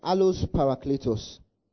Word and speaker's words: allos parakletos: Now that word, or that phrase, another helper, is the Greek word allos parakletos. Allos parakletos. Are we together? Allos parakletos. I'll spell it for you. A-L-L-O-S. Then P-A-R-A-K-L-E-T-O-S.